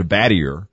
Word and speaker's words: Battier: The Army had their own interpretation of B-A-T-T-I-E-R. So all my dad's records got changed to Battier. Met my mom in 76. She said, you know what? Looks French. We're going with the French pronunciation. – All right. – So that's how I became Battier.